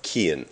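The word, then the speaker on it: Cian: but it's pronounced Kian, like Ian with a K.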